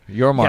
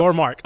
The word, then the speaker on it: Yormark: Yormark. Yes, yep. Yormark.